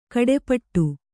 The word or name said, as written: ಖಟಿಪಿಟಿ